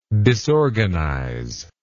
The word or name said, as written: disorganizer